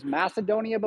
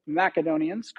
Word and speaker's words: Macedonia: You may have heard it as Macedonia before. That's the way a lot of Americans pronounce it, but the original Macedonians called it Macedonia.